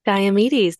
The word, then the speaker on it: Diomedes: So we have, again, I hope I'm saying this right, Diomedes, that's how they say it on the audiobook.